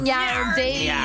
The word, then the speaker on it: Gardaí: I was on Dawson Street and I saw two Gardaí on horseback. – Say it correctly. One Garda, two Nyardi.